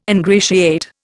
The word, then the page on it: ingratiate: Indian